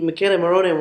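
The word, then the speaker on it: Michele Morrone: I would say the Michele Morone one is... it stands out. – Okay. I just butchered his name, didn't I? – No. I'm saying it the way he introduced himself to me.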